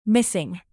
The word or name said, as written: missing